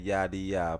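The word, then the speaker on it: Yaddiya: Follow me at Yadi Ya. Please don't call me your dear. – You want to spell it because everybody. I'm Y-A-D-D-I-Y-A. Y-A-D-D-I-Y-A. Y-A-D-D-I-Y-A.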